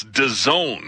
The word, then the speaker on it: DAZN: I guess it's DAZN, right? – I think that's their preferred pronunciation, yeah.